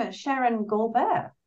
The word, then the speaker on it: Sheren Gaulbert: So welcome, Sheren Gaulbert.